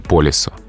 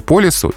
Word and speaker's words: по лесу: Потому что а, словарь Зарвы, русское словесное ударение, обе формы считает правильными. То есть и по лесу, и по лесу.